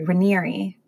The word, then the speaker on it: Raniere: So Keith Raniere, or sorry, Ranieri, that's how you pronounce it.